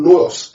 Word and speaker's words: nous: The first word, understanding, there is the Greek word nous, N-O-U-S.